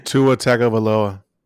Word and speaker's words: Tua Tagovailoa: The Miami Dolphins, after starting the season 3-3, have now decided that it is time to start their first round pick, Tua Tagovailoa. Can you guys pronounce that, by the way? – Tua Tagovailoa. – Yeah, is that how you pronounce it? – That's how you say it.